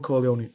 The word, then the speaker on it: Corleone: Michael Corleone.